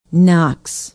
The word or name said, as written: knox